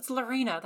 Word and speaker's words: Lorena: Lorena. That's how you say it.